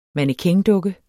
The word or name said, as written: mannequindukke